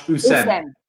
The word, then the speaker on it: Hussein: Just first, Sophie, I know you're confident with the Hussein, but you mustn't pronounce the H. So it's very much Hussein.